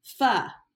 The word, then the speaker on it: pho: I was putting on mascara during the faux voicemail, or pho. It's pho. That's pho. How you say it, pho.